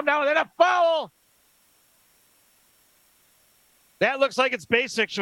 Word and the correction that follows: Basich: with the call. Bennett Basics. Basich, maybe. That's his first. Bennett Basich is the pronunciation.